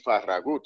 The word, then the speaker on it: Farragut: His name was David Farragut, more accurately pronounced David Farragut, whose biological father was a Spanish soldier who fought for the Patriots during the War of Independence.